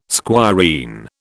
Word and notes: squireen: British English

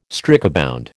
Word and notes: strikebound: American English

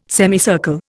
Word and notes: semicircle: Indian

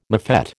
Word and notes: mofette: American English

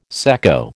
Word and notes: secco: American English

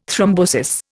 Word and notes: thrombosis: Indian

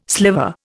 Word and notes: sliver: Indian

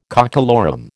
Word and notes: cockalorum: American English

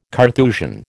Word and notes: Carthusian: American English

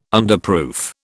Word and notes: Underproof: British English

